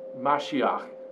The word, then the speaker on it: Mashiach: See, the word Messiah comes from a Hebrew word that's pronounced something along the lines of Mashiach.